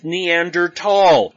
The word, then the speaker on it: Neanderthal: Okay, the T and the H in Neanderthal are together, but they're not pronounced together. It's pronounced Neanderthal.